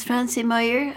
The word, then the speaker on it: Franzi Meyer: Now I have a guest from Germany with us this morning, and her name is Franzi Meyer. Am I pronouncing it correctly? – Yes, very yeah.